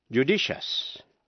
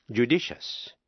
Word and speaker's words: judicious: Nếu trước đuôi IOUS có âm T, C hay X, thí dụ, judicious, judicious, J-U-D-I-C-I-O-U-S, judicious là sáng suốt.